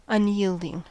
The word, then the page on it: unyielding: American English